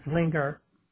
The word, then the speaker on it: Zwiener: Lastly, some statements about the record of State Representative Aaron, tell me if I'm pronouncing this correctly, The last name is Z-W-I-E-N-E-R. Aaron. – Yeah, Aaron Zwinger.